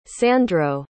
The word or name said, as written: sandro